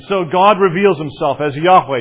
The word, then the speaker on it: Yahweh: His name is Yahweh. Anglicized, it is sometimes pronounced Jehovah.